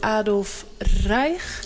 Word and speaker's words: Adolf Reich: En deze is geschilderd door Adolf Reich, zeg ik dat goed? Ja. Is dat een van de favorieten ook van Hitler in die tijd? – Jazeker, want hij had heel veel favorieten.